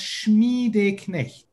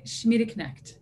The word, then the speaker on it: Schmiedeknecht: Well, the last name is pronounced Schmiedeknecht, but sound at the end, it doesn't exist in the English language.